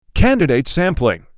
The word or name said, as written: Candidate sampling